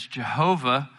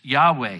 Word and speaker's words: Yahweh: was in Hebrew was pronounced as Jehovah, most scholars today think that the actual pronunciation is Yahweh.